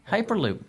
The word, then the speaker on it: Hyperloop: Am I saying it right? – Yeah, Hyperloop.